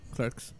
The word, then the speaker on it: Clerks: Clerks, correcto.